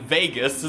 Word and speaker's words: Vegas: Ah, the papers of Vagas. – Ah, yeah, Vagas, yeah. – It's been 2 years since the bombs, and we've already started mispronouncing Vegas as Vagas.